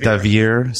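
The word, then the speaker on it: Deavere: Anna DeVry, is that how you say it? Devere Smith.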